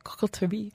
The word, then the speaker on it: Coterie: It is spelt, this is where the French people are like, it is coterie.